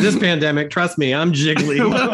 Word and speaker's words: Gigli: And then last but not least, it is probably the most mispronounced movie in all of cinema history. It is not Gigli. It is not Gigli. It is Gigli.